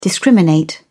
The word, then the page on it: discriminate: British English